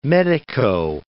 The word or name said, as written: medico